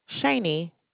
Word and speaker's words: Shaney: Shani. – How do you spell that? – S-H-A-N-E-Y.